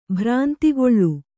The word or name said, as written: ಭ್ರಾಂತಿಗೊಳ್